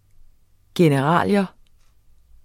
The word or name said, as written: generalia